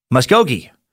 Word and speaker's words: Muskogee: In the mob arc of Timesuck, like you struggle with the town of ah, uh, uh, Muskogee, Oklahoma. It's pronounced Musk as in men's cologne, O as in the noise you make during orgasm, and G, uh, Musk OG, as in the sound you make after saying the Irish name McGee.